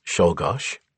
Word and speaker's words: Czolgosz: Of all the presidential assassins, Leon Czolgosz, pronounced Czolgosz, had the clearest motive.